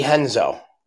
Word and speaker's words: Renzo: The R's in Brazil are pronounced as H's. – Brazilian? Brazilian jiu-jitsu? I'm just going to say Brazilian. – No. What's the name of the place? Did you say Renzo? – Yeah. – Yeah, that would be Henzo.